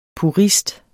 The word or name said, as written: purist